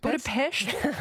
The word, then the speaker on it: Budapest: How do you say Budapest? – Budapest. – Very good.